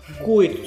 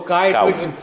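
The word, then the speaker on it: Kuyt: Τελικά πώ προφέρεται ο Dirk Κούιτ, τώρα θα το πω εγώ, Κάουτ. Κάουτ, διαβάζετε, αλλά όλα αυτά τα ονόματα,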